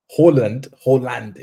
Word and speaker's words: Haaland: Haaland, in fact, is the correct way to pronounce it. Like, that's how you actually pronounce his name.